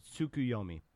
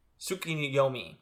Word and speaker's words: Tsukuyomi: So this is when we kind of first learn of the Susukoni? How do you pronounce that? – Tsukuyomi. – Tsukuyomi.